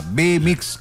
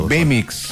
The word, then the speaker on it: Bemix: No. Pero B corta, B mix con X final. – Entonces, pronuncie bien la B corta. – B mix.